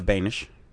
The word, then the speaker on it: Benisch: I'm Erica Benisch.